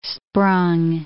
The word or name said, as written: sprung